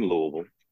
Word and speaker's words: Louisville: I'm actually born in Louisville. Grew up in the area all my life. And I tell people, you know that's true because I pronounce it Louisville and not Louisville.